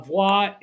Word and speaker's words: Voix: How do you say it? – Voix Lounge. – V-O-I-X. Voix Lounge, 2825 Cotman Avenue.